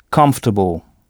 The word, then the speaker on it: comfortable: Pas de son on, puisque ce son n'existe pas en anglais. Et ici, l'ensemble C-O-M se prononce come. Et notez que O-R est à peine audible, comfortable, et que c'est la première syllabe qu'il faut frapper. Comfortable. Bon, alors prenons un autre exemple. Si sœur se dit sister, S-I-S-T-E-R, comment dira-t-on « votre sœur a fait un travail remarquable » ?